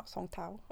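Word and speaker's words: songthaew: Men Song Tao, hur säger man? – Ja, Song Tao. – Song Tao, alltså, ja.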